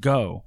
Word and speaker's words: Geaux: G-E-A-U-X Gaming. Yes, the network is pronounced Go, like faux, but it's the Cajun way of saying Go, like as in...